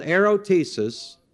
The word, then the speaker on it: erotesis: And I'll spell it for you, called erotesis, spelled E-R-O, T-E-S-I-S, erotesis, E-R-O-T-E-S-I-S.